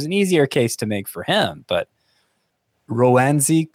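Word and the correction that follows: Roansy: Contreras. Is that how you say it? I believe it's Ronzi. Ronzi? Yes.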